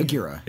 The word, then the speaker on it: Aguirre: Played by Rigo Sanchez and I hope I'm pronouncing this right, Josue Aguirre. – Aguirre? – A-G-U-I-R-R-E? – Aguirre. – It is Aguirre.